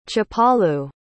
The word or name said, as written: chapalu